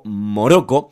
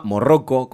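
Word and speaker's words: Moqorro: va a levantar al sacerdote rojo Morocco, que estaba Morroco, ¿cómo lo, lo pronunciabas vos? – Eh, me parece que en realidad es mocorro, una así.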